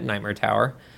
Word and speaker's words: Knightmare Tower: It is spelled K-N and then, you know, the rest of it, Nightmare Tower. Like a knight.